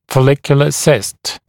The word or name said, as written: follicular cyst